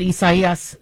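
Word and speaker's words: Isaías: So, you know, the media is reminding us that we are pronouncing it Isaias, so, because that is the Latino term for Isaiah. And so even though Isaias is where how many of us Americans would call it, the Spanish pronunciation is what they are using.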